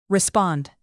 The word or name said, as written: respond